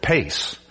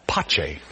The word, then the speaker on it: pace: You write that word pace, but it's pronounced pace.